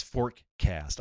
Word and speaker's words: Forkast: Two students that spoke to Forecast said, or it's Forecast. I love that. Okay. The people writing this for Decrypt is the Forecast.news. Forecast is spelled F-O-R-K-A-S-T. Fork. Get it?